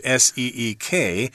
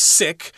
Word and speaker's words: Siq: Okay, it's not like the English S E E K. Uh, we're pronouncing this as Seek. It's possible it's pronounced sick, but I don't want to say it that way because that means you're ill and you need to see the doctor and stuff like that. So we're going with the pronunciation Seek here.